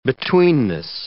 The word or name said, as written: betweenness